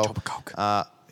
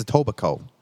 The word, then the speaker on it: Etobicoke: You said it right, but I think the K is silent. – Etobicoke? – Etobicoke. I think it's Etobicoke.